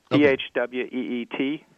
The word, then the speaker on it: Phweet: Already, within 24 hours of American Airlines launching the service, flyers were boasting about how they had used a service called Fweet, which is a variation of the Twitter service. – Now, Fweet is spelled Phweet, right? – Yes, Phweet, and added .com on there, and you can go to the site.